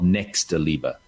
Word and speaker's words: Nächstenliebe: In Germany, it's called Nächstenliebe.